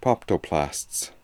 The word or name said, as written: poptoplasts